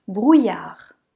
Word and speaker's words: brouillard: Le suivant, brouillard. Brouillard. Brouillard. Ici, le i et les deux l se prononcent y. y. Ensuite, on a le son a et r, car le d ne se prononce pas.